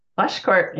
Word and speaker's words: Pleschourt: Pleschourt, yep, that's right.